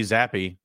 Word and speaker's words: Zappe: Bailey Zappe, that's how they pronounced it. We couldn't get the pronunciation right last week, but uh, was a live option for them 10 for 15, 99 passing yards.